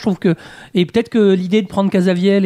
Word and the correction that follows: Caviezel: et... C'est ça comme ça que ça se prononce? – Caviezel, pardon, bon bref.